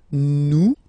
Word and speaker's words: nous: Here it's nous, nous. Okay, ah, sorry about that. Oops. Nous sommes. Okay, nous sommes. Okay, so you don't pronounce the final S here, it doesn't exist.